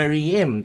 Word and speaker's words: Maryam: 好，最主要的是那个，就是一个在东非乌干达的一个女人叫做玛丽姆，她在对玛丽妹啊，她发音什么 Maryam 对，然后就是她的就是在过去一共生了 44个孩子。